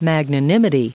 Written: magnanimity

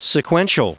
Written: sequential

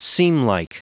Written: seamlike

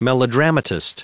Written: melodramatist

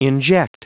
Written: inject

